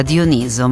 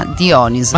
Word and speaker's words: Dioniso: Si pronuncia con l'accento sulla penultima sillaba, Dioniso, ma in italiano è considerata corretta anche la pronuncia sulla, sulla terzultima, Dioniso.